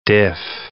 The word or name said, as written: diff